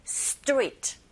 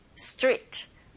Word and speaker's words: street: S 사운드와 t 또는 S 사운드와 k 또는 S 사운드와 p 이렇게 두 가지씩 연속적으로 들리게 되면 두 번째 자음의 소리가 된소리 현상이 일어납니다. 그래서 스트리트가 아니라 street, street 이렇게 발음해 주시면 훨씬 더 발음하기 편하세요.